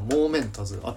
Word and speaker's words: Momentous: モーメンタズ発音合ってるかなモーメンタズ合ってるね